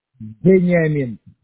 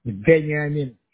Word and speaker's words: Veniamin: Benjamin. Start with a V, Dickman. – With a V? – Yeah, V. Like a Victor. Benjamin. – Okay, how do you spell that? – V-E-N-I-A-M-I-N.